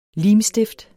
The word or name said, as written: limstift